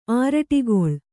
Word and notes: ಆರಡಿಗೊಳ್: āraḍigoḷ

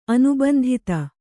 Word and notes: ಅನುಬಂಧಿತ: Kannada

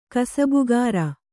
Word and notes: ಕಸಬುಗಾರ: kasabugāra

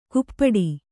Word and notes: ಕುಪ್ಪಟಿ: kuppaṭi

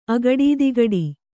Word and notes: ಅಗಡದಿಗಡ: agaḍadigaḍa

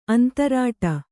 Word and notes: ಅಂತರಾಟ: antarāṭa

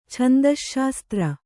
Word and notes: ಛಂದಶ್ಶಾಸ್ತ್ರ: kannada

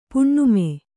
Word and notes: ಪುಣ್ಣಮೆ: puṇṇame

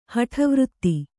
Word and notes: ಹಠವೃತ್ತಿ: kannada

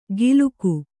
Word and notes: ಗಿಲುಕು: giluku

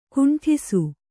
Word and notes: ಕುಂಟಿಸು: kannada; kuṇṭisu

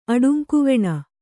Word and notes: ಅಡುಂಕುವೆಣ: kannada

